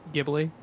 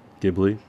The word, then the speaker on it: Ghibli: Um, there's a movie 2016 came out by one of my favorite animated studios, Studio Geobly. I can never say it. Geobly. Can that, can either of you pronounce it correctly? Ghibli. Ghibli. Thank you.